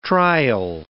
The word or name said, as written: trial